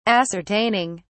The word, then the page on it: ascertaining: British English